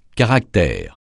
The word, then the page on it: caractère: francais